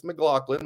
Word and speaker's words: McLaughlin: He was like, it's McLaughlin, like Sarah McLaughlin.